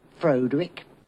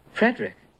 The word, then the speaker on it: Frederick: Do you also say Frodrick? – No, Frederick. – Well, why isn't it Frodrick Frankenstein? – It isn't, it's Frederick Frankenstein.